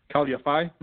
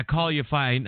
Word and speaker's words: Khalid Yafai: Um, that's uh, Khalid Yafai. Did I say that right? Khalid Yafai? Mm-hmm. – Yeah, Khalid Yafai.